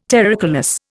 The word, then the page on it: terricolous: Indian